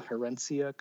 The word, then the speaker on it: Herencia: Maybe S- spell it. – Uh, H e r e n c i a. – Yeah, herencia, the inheritance.